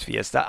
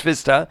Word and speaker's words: Vista: Ich habe es auch gedacht, es heißt Fiesta, aber es heißt Vista.